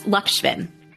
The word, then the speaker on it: Lakshmin: Yes, Lakshmin.